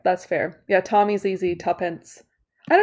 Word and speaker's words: Tuppence: And so their names are Tommy and Tuppence. – Sorry, what was that second one? – Tuppence. – Can you spell that for me? – They spell it T-U-P-P-E-N-C-E.